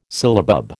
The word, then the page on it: syllabub: American English